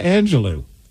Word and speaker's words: Angelou: I don't know what you said, but I said Maya Angelou.